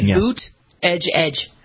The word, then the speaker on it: Buttigieg: He would say boot edge edge.